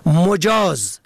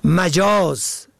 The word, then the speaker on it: مجاز: مجاز به ضم اول اجازه داده. مجاز به فتح اول غیر حقیقی که در تلفظ آن باید دقت شود.